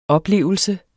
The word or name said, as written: oplevelse